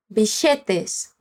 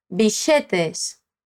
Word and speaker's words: billetes: G-U-I, gui, guita. Já. E bilhetes aqui significa passagens. Repete, bilhetes. Mas ela não fala os dois L's como a gente falou agora. Ela fala com o som de sh, bichetes. Bora lá tentar. Bichetes. Esse LL pode ter várias pronúncias diferentes em espanhol. Uma das que a gente escuta na Argentina é essa.